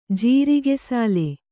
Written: ಜೀರಿಗೆಸಾಲೆ

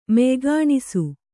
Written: ಮೆಯ್ಗಾಣಿಸು

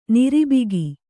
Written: ನೀರುಬೊಕ್ಕೆ

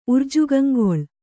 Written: ಉರ್ಜುಗಂಗೊಳ್

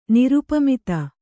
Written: ನಿರುಪಮಿತ

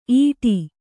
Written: ಈಟಿ

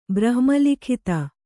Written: ಬ್ರಹ್ಮಲಿಖಿತ

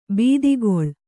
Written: ಬಾತುಗಾಲು

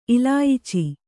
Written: ಇಲಾಯಿಚಿ

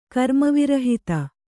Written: ಕರ್ಮವಿರಹಿತೆ

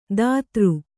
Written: ದಾತೃ

